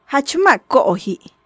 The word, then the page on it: Jach ma' ko'oji': Maya